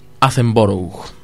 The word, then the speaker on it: Attenborough: Y ahora me toca hablar de esta película británica, estrenada en 1977 y dirigida por Richard Attenborough. No lo dije mal.